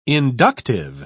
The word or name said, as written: inductive